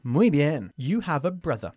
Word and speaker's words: brother: Una palabra que a veces pronuncian mal los españoles es brother. No se dice brother. Brother. – Brother. – Bajando una vez más la mandíbula, mm-hmm, para producir este sonido.